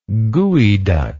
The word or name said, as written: gweduc